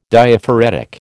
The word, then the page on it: Diaphoretic: American English